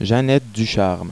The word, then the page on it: Janette: french pronunciation